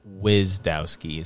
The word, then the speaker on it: Gwizdowski: Gwizdowski is how I say it. I don't say the G.